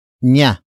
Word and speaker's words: niya: The next word is nya. Nya. Nya. Nya is spelled as N-I-Y-A.